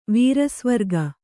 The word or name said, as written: ವೀರಸ್ವರ್ಗ